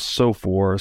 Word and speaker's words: SOFR: Now, this is a bit more technical, but this transition from LIBOR to SOFR. I guess is how you pronounce this acronym, S-O-F-R. SOFR. Yeah. So I don't know a lot about this.